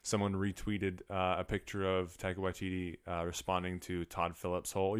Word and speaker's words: Waititi: They kept saying his name Taika Waititi at the Oscars. They kept just like pronouncing the TT a lot.